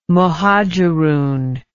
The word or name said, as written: Muhajirun